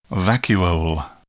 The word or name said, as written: vacuole